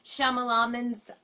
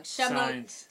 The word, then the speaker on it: Shyamalan: Shyamalan's, I'm pronouncing his name wrong. – Shyamalan's.